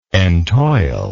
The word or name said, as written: entoilment